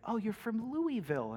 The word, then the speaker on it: Louisville: That's that way, and yes, it is pronounced Louisville.